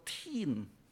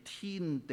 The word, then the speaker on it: Teen: 讀音的天，即是你聽起上來就好像天地的天，但你寫出來是英文來的， T-E-E-N，即是青少年，